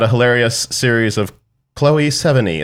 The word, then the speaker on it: Sevigny: Although I think one interview, she said it's like the number seven and then the, on your leg, your knee. So seven knee.